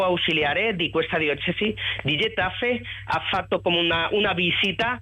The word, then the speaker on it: Getafe: Ho detto bene, Getafe, benissimo.